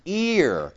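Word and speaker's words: ear (ἀήρ): In any case, that's the word alpha eta rho, and it's pronounced ear, and it has to do with the arena.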